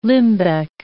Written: limbeck

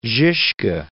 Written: Zizka

